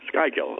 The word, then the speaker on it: Schuylkill: And am I pronouncing that? – Schuylkill. – Schuylkill, okay.